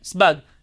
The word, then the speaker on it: Sebag: Today they'll call him Sebag, Asebag, Sebag, but when I heard Rabbi Shalom Assas say his name, he called him Rabbi Yitzchak Sebag, like that, Sebag. That's how he pronounced his name.